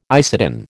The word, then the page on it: isatin: American English